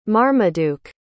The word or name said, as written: marmaduke